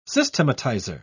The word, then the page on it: systematizer: British English